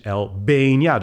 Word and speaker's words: Albania: Maar het is Germany. En het is Albania. Dus in plaats van dat de klem dan op de eerste lettergreep ligt, ligt hij op de tweede lettergreep.